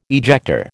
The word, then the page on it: ejector: American English